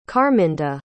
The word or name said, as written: carminda